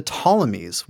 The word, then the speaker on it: Ptolemies: Judea, or Israel, is ruled over by the Ptolemies with a silent P. So it's not the Ptolemies, it's just the Ptolemies.